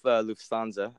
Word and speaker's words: Lufthansa: Like we flew with Lufthansa.